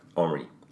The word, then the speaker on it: Henri: they don't pronounce the H right. Henri. – Henri, yeah, sorry.